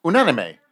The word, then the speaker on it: Unanime: Just corrected my pronunciation. I'm usually a bit of a bitch at correcting his pronunciation when he does French. Say that again, please. – Unanime. – I think that's correct.